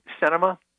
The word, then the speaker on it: Sinema: Is it Sinema? And I pronounce it, mispronounce her name, and I apologize. – I think that's right.